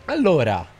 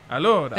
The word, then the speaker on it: allora: Allora. – Allora.